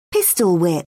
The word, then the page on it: pistol-whip: British English